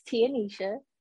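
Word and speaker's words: Tianisha: Hi everyone, it's Tia Nisha. That's T-I-A-N-I-S-H-A.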